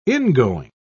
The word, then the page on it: ingoing: British English